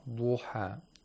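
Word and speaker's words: Duha (الضحى): Laud is a letter of isti'la. Yes. It has to be pronounced with tafkhim. – Thickness. – With thickness. Wal-Duha, Duha. We don't say Wal-Duha, okay, without tafkhim. We have to make sure that we apply the tafkhim to this letter, which is Laud.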